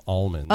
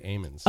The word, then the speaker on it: almonds: There's a little Easter here, you know, in the Central Valley of California. That's how they pronounce the growers of these types of nuts pronounce them. We would call them almonds. – Oh, okay. – They say amens.